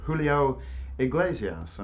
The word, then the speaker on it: Julio Iglesias: Julio Iglesias.